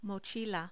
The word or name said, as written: mochila